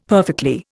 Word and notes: perfectly: Indian

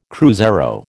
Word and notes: cruzeiro: American English

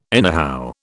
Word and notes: anyhow: British English

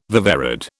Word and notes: viverrid: British English

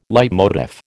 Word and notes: leitmotif: American English

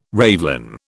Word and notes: ravelin: British English

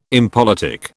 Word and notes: impolitic: British English

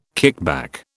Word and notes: kickback: British English

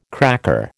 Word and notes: cracker: American English

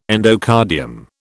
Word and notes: endocardium: British English

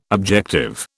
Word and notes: objective: British English

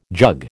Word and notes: jug: American English

